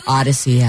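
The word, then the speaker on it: Audacy: Download the free Odyssey app. Yeah, there it is. Odyssey, A-U-D-A-C-Y. I know it's spelled funky.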